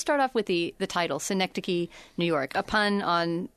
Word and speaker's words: Synecdoche: I know that Synecdoche, pronounced Synecdoche,